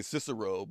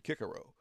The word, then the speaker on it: Cicero: So we say Cicero, but the Latins, the Romans said Cicero.